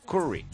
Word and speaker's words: Curry: El otro día entró un periodista desde Los Ángeles que pronuncia Curry, pero claramente con U, no con A. Curry.